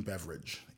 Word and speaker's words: Beveridge: His name is Jim Beveridge. It's pronounced like the drink, but it's spelled differently.